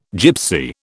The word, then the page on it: Gypsy: British English